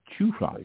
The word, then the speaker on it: Chufly: Chew fly, yeah. Mm-hmm. And the emphasis is on the fly.